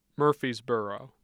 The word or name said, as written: murphysboro